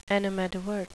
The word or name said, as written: animadvert